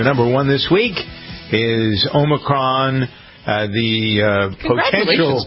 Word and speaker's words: Omicron: For those of you who didn't hear, I was calling Omicron. – Omicron, like a tech company.